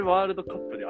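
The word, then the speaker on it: W杯: 確かに、あれってさ、 W 杯って読む人いないもんね。ワールド杯って読む人いないもんね。 – そう、あれワールドカップであれで読ませるの、なんか、おしゃれだな。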